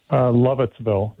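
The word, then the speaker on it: Lovettsville: How do you say it, Lovettville? – Lovettville, Virginia. – Yeah, very good.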